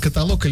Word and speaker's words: каталог: Как правильно говорить, каталог, каталог или допустимо оба варианта? – Каталог.